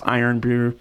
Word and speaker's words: Irn-Bru: So you spell it IRN-BRU, pronounced Iron Brew.